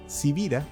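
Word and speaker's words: Sibira: Civira es mi apellido, y que no es tan común, se escribe con S de Samuel y con B de Victoria.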